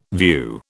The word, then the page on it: view: British English